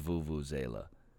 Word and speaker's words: Vuvuzela: What is that shit called? Vuvuzela? Zuvuzela? And how the fuck do you spell it? Vuvu. Okay. Yeah, it is Vuvuzela. V-U-V-U-Z-E-L-A. Little Vuvuzela, eh?